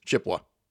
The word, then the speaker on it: Chippewa: And of course, here where I'm sitting right now, you never pronounce the E in Chippewa Falls, it's Chippewa.